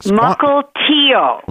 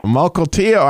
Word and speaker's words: Mukilteo: But we got in, I think it's pronounced Mukilteo, Washington. I think Mukilteo. – Mukilteo.